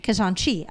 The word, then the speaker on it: Kashanchi: Kashanchi.